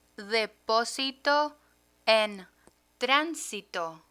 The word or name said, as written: deposito en transito